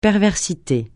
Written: perversité

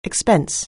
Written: expense

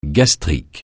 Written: gastrique

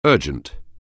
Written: urgent

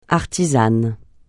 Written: artisane

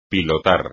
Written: pilotar

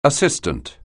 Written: assistant

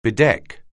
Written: bedeck

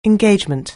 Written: engagement

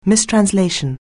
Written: mistranslation

